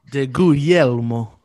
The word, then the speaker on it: DeGuglielmo: DeGuglielmo? I don't even know. – DeGuglielmo? Or if you're Italian. DeGuglielmo. Yeah. That GLI. That's how you say that in Italian.